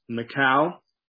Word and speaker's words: Michal: So he gets home, and he has, I'm sure, a very spirited conversation with his new wife, Michal. Remember, I decided to pronounce it that way. I think in the Hebrew, it's allowed.